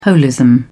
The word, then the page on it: holism: British English